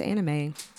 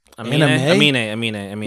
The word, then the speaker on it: Aminé: I don't think it's anime. – Aminé.